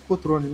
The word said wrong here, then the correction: Crotone: né? Aí eu falei errado. Crotone.